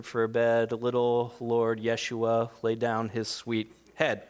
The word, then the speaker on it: Yeshua: See, the name Jesus is actually a Latin translation of a Greek name, which is translated from a Hebrew name, Yeshua.